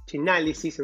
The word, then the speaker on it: Chainalysis: Está Chain Analysis, Chainalysis en realidad, chain Chainalysis, no me sale muy bien la pronunciación.